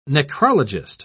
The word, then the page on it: necrologist: British English